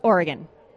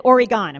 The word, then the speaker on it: Oregon: Or actually, I was born in Oregon, and that is how you say it, just Oregon. Someone said Oregon. I'm like, no one does that.